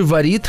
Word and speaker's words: варит: Кстати, варит и э, звонит, это изначальная форма, так было правильно Говорит Сейчас варит правильно говорит, да, варит, звонит, также варит и звонит скоро. Будет нормой, видимо, по, по примеру, варит.